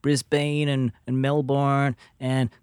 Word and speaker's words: Brisbane: I believe it's pronounced Brisbane. – Brisbane.